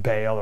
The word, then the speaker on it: Baal: Dagon was the father of Baal, B-A-A-L, perhaps you've heard it pronounced Baal or Baal, it's Baal.